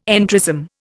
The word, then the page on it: entrism: Indian